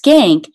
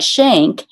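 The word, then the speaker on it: Schenck: W-H-I-T-N-E-Y, and then hyphen, and my husband's name is pronounced Skank. It's a Dutch name, but it looks like Shank, and it's S-C-H-E-N-C-K.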